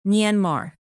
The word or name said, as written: myanmar